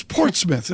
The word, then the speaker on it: Portsmouth: It's Portsmouth.